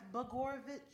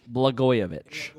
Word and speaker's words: Blagojevich: I believe it's pronounced Blagojevich. – Blagojevich.